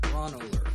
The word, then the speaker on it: Braunohler: Just wanted to pop in real quick to just to let everybody know that some of us here understand that it's actually pronounced Kristen Schaal and Kurt Braunohler.